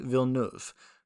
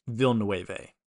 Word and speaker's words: Villeneuve: Today, we'll be reviewing Dune 1984, directed by David Lynch, and Dune 2021, directed by Denis Villeneuve. And I did practice how to say his name before recording this because I did before this call him Denis Villeneuve.